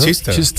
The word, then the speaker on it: Chichester: Como el eso sería, se pronunciará Chichester o algo así, ¿no? – Chichester, claro, ¿no? Chichester, porque... – ¿Qué pasa? Porque le preguntas a mí, ¿no? – No, hombre, porque no, tú también tienes idea de inglés. Lo que pasa es que por el rollo del inglés, el Leicester, ¿no? Se escribe el Leicester.